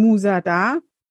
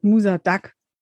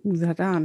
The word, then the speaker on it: Musa Da: Ihr müsst mir als allererstes mal sagen, ich würde eure neue Band aussprechen, Musa Da, aber ich habe jetzt ganz oft gehört, Musa Duck. Wie sagt man denn? – Da ist richtig. – Musa Da, ne? – Also, das ist das Duck.